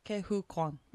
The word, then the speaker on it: Ke-Huy Quan: Do you know how to pronounce his name? I really don't want to butcher it. Ke-Huy Quan. There we go.